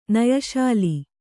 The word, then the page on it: ನಯಶಾಲಿ: nayaśali